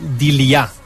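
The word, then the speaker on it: Dilja: La interpreta la jove Dilia. Crec que ho dic bé, va amb una jota, però això es pronuncia com una i en català.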